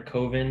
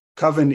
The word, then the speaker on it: coven: I mean, I just realized that this movie is the reason why I've been mispronouncing or saying it the way that Mark says it for so many years. I definitely say Coven by default. But I guess Coven is the appropriate one, but I think Coven probably is accepted.